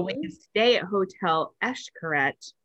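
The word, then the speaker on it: Xcaret: So it's like I would always say Eshkaret, but it's actually Eshkaret. I don't know. I'm probably still not saying it right, but it's like instead of an X, I guess.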